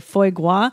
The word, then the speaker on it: foie gras: Second one is it mussels in a foie gras style sauce. – Listen, you could put I didn't know how to say it, foie gras.